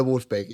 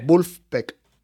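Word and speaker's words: Vulfpeck: O sea, tú le pones un disco de Wolfpack,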